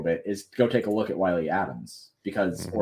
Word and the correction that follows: Adames: Willy Willy Adames.